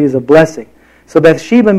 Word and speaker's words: Bathsheba: The name Bathsheba, and it's not Bath. It's unusual that her name is associated with taking a bath, and it's in the Bible. It's actually Beth. Bathsheba is the way you would pronounce that.